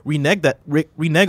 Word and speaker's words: renege: Did Activision Blizzard renege on a deal, or did Nvidia fail to get permission? – Even though renege is spelled incorrectly here, you got that exactly right.